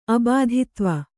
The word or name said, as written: ಅಬಾಧಿತ್ವ